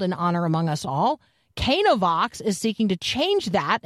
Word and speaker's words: Canavox: And so it, Cana, like, right, the wedding at Cana, that's what's in view here. Cana Vox.